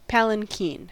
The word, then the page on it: palanquin: American English